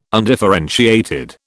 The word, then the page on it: undifferentiated: British English